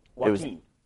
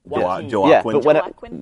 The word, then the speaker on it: Joaquin: Joaquin. – Was it? – Yeah. – Joaquin.